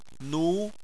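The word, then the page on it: nu2: tone 2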